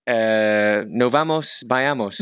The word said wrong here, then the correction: Novos Baianos: Novos Baianos.